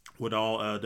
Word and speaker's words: Woodall: Woodall, W-O-O-D-A-L-L.